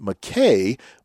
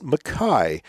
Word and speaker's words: Mackay: His last name is spelled M A C capital K A Y, which you would think would be more pronounced Mackay, but it's Mackay.